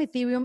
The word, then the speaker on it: Ethereum: Sí, Ethereum. – Ok. Cuéntanos Ethereum y por qué dices que tiene mucha más flexibilidad a la hora de utilizarla digitalmente.